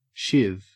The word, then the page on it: shiv: Australian English